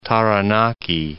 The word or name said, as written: Taranaki